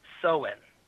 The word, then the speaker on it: Samhain: However, he pronounces the word literally, which is incorrect. It's actually pronounced Sowen.